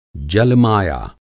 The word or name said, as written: जल-माया